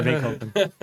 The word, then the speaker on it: Bain Company: Como é que era lá, amor, quando você estava na Bain, na Bain Company? Não sei, falei, falei certo? – É? Sim, sim, na Bain Company.